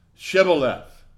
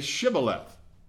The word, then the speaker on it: Shibboleth: The people of Ephraim pronounced Shibboleth. They left the H out.